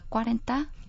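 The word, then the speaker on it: cuarenta: 뭐 스페인어를 하고 싶으시면 루타 꽈렌타 꽈렌타. – 음, 루타 꽈렌타.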